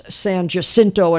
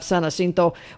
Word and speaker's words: San Jacinto: Just like it's Amarillo and not Amarillo and all that, and just like it's San Jacinto and not San Jacinto.